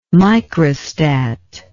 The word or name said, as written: microstat